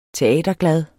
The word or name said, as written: teaterglad